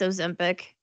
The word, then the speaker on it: Ozempic: That's how you pronounce it. – It is. It's Ozempic.